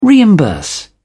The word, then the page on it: reimburse: British English